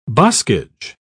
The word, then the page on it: boskage: British English